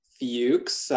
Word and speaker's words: Fuchs: Like you said, my name is Jason Fuchs. It's pronounced Fuchs. I recognize the spelling for some people could be a little difficult.